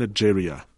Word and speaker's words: ageria: The first respected doctor to give it one, back in the 1890s, called it an ageria, with a soft G, but for reasons that will become clear, that never became public knowledge.